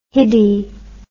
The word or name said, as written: hiddy